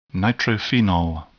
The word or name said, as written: nitrophenol